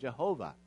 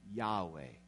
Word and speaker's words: Yahweh: And so because of that, they started eventually, when they wanted to try to figure out how to pronounce it, they came up with the pronunciation of Jehovah. But maybe a little more accurate way to say it, how they might have said it originally, would be the form that we know as Yahweh.